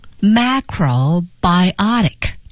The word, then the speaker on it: macrobiotic: Macrobiotic。 – 首先我们来看它的发音啊，这个大家呢来标一下，把重音放在 biotic 这个地方。